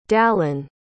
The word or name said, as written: dallan